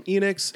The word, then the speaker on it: Enix: My understanding is it's Enix like Phoenix. – Okay, that would make sense. Yeah, so we got, so this comes to us from Enix and developer Produce, which I've seen spelled with an exclamation point, so I'll just keep saying it.